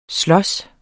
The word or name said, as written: SLOGES